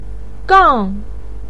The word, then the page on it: gang: 4